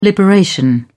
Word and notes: liberation: British English

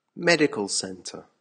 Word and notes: medical center: American English

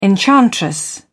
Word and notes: enchantress: British English

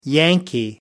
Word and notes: yankee: American English

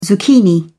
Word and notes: zucchini: British English

